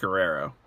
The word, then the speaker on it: Guerrero: Is it Herrero? Herrero? Am I saying that right? – I'm pretty sure it's just Guerrero. – Okay, it's just Guerrero. All right, so we have been saying it correctly for the last 30 years.